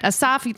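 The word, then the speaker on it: Assaf: But Asaf, listeners, we'll put it in the show notes, but it's A-S-S-A-F.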